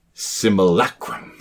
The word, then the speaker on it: simulacrum: You are very astute, but it's pronounced simulacrum. Say it with me. Simulacrum.